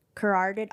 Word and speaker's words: carotid: Carotid artery. – Carotid. – Carotid artery. Is that am I saying that right? – now? Carotid. It's like karate, karate.